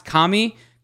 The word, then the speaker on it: Kame: This was a ported game that was originally released in Japan as TMNT Super Kame Ninja. I don't know. Probably probably pronounced Kame, but it's K-A-M-E. Super According to Dragon Ball Z, it is Kame.